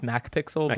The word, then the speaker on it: McPixel: McPixel. You remember McPixel from way back when. – I think when. – It's pronounced MacPixel. MacPixel. Yeah, go for it, bro.